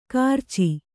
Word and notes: ಕಿರಚು: kannada

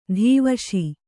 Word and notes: ಧೀವಶಿ: dhīvaśi; noun

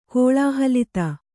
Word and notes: ಕೋಳಾಹಲಿತ: kannada

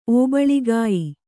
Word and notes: ಒಬ್ಬುಳಿಗೆಯ್: Kannada

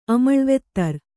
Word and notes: ಅಮಳ್ವೆತ್ತರ್: Kannada

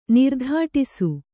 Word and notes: ನಿರ್ದಾಟಿಸು: nirdāṭisu; Kannada

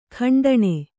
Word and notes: ಖಂಡಣಿ: khaṇḍaṇi

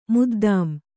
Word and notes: ಮುದ್ದಾಂ: kannada

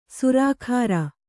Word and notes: ಸುರಾಖಾರ: surākhāra

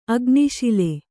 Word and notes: ಅಗ್ನಿಶಾಲೆ: agniśale; Kannada

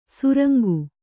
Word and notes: ಸುರಂಗು: surangu

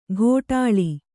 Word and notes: ಘೋಟಾಳಿ: ghōṭāḷi

